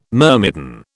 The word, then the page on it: myrmidon: British English